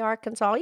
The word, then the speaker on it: Arkansas: Arkansas looks like Arkansas, but you don't say Arkansas. You say Arkansas. You know, Kansas, that's another state. – Well, Pinky, it's because of the French pronunciation. The French do not include the letter S at the end of the word, so that's why it's Arkansas.